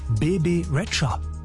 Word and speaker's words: Rexha: aber eigentlich so richtig auf Albanisch wird sie Bebe Rexha ausgesprochen. Als wäre da noch ein D irgendwo, aber gut, wieder was dazugelernt.